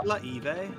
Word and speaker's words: glaive: It's pronounced glaive.